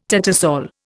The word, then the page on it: tattersall: Indian